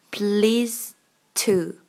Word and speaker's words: pleased to: 但是的和 t 都是爆破音，所以第一个的我们不要了，念成 please to, please。 to please to 没有 pleased to 啦，是直接 please to 第一步发音。